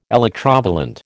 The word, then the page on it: electrovalent: American English